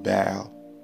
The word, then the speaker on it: Baal: Now, the word Baal, it's spelled B-A-A-L. Some people pronounce it as Baal, some other people pronounce it as Baal. I just want you to know that it's the same word.